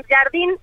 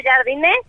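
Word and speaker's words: Jardine: Sí, porque la verdad es que yo estuve investigando, porque los reporteros que lo entrevistaban en San Luis le decían Jardín, entonces pensamos que era así, después lo escuchamos pronunciarlo y él no lo pronunciaba así, lo pronunció Jardiné, y después resulta que ya Baños abre la conferencia diciendo, primero que otra cosa, les vamos a explicar y les vamos a decir cómo es. Tiene como acento en la I, Jardiné, para que se lo digan a todos sus compañeros y ya no haya dudas de la pronunciación.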